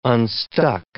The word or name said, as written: unstuck